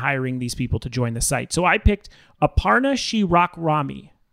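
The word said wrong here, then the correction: Shewakramani: I almost said it wrong. Let me say it again. Aparna Shirakramani.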